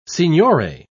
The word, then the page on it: signore: British English